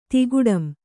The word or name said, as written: ತಿಗಟಂ